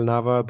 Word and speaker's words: Nava: So my name is my name is pronounced Angel Nava, but a lot of people do call me Angel.